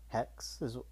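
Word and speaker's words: Haxe: It's called Hex. That's H-A-X-E. Hex is how they say it.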